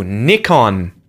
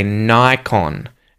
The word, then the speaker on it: Nikon: I believe many people pronounce it correctly, but I sometimes hear people say Nikon. – Maybe they say Nikon just like us. – But you know, this is not correct. Yes, we pronounce it Nikon.